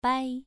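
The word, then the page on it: bai: tone 2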